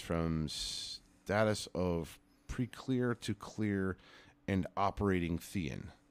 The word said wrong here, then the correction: Thetan: Thetan. – Thetan. – I think that's how it's pronounced.